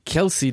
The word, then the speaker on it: Kelce: Kelce, Digga.